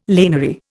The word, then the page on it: laniary: Indian